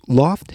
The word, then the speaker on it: L0pht: So Loft Heavy Industries, that's spelled L0PHT, was the Boston equivalent of Cult of the Dead Cow.